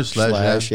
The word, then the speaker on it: Schlage: But we use like the Slash encode. I don't even know if I'm saying that right. – Schlage. Yeah, Slash.